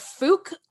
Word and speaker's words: Fouke: So the Boggy Creek Monster is also referred to as the Fouke Monster. I don't know if I'm pronouncing that correctly. It's F-O-U-K-E. – F-O-U-K. It sounds right.